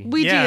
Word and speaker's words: Ouija: One thing I did, I always feel weird whenever I pronounce you know, I read that it was named after yes in French and yes in German, so it should be pronounced Ouija. – Yeah. – But nobody fucking does that. – No. – On the planet Earth. – Ouija is fine. – Ouija, it's like a squeegee.